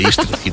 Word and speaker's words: Истринский: Да, под Подмосковье за Истринским районом. Правильно сказал? – Шикарно, Истринский, да. Есть такое, там воды много.